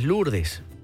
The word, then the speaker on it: Lourdes: Dice por acá, correcto, Deidania Villalobos nos dice por acá, bueno, que la pronunciación es Lourdes.